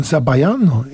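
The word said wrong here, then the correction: zabaione: Zabajone. Zabajone.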